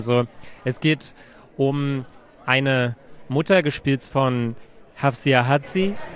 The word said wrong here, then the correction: Hafsia Herzi: Ist das korrekt ausgesprochen? Hafsia Herzi. – Herzi, okay, ja, genau.